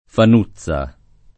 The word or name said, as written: Fanutza